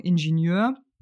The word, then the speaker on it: Ingenieur: Aber die Deutschen würden das R am Ende natürlich nicht mit aussprechen. Wir sagen Ingenieur.